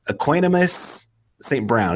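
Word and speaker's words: Equanimeous St. Brown: And this is one guy that you actually brought onto my radar, and that is Equanimous St. Brown. Is that right? – Yes, you're correct.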